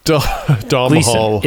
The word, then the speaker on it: Domhnall Gleeson: We're both gonna butcher it. Gonna butcher this guy's name? But I think it's Domhnall Gleeson. – Yes, there it is. – Or Domhnall Gleeson. – Domhnall. – It's definitely Gleeson, right? – Yes, exactly. It's just the guy from About Time.